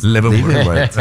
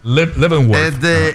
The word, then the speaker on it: Leavenworth: Leavenworth. – Qué bien lo pronuncias. – Leavenworth.